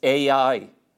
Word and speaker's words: Ai: And this little place, this valley of Achor, is only about 10 miles from Jericho, and it's outside a place called Ai. That's the correct pronunciation of it. Not I, it's Ai.